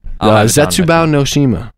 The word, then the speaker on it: Zetsubou no Shima: Zetsubou no Shima.